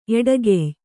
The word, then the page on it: ಎಡಗಯ್: eḍagay